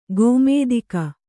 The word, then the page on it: ಕುಮುದಿಕೆ: Kannada